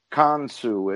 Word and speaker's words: Khonsu: They're called Kansu. It's spelled Khonsu, and they're avant-garde like that, and I really like them.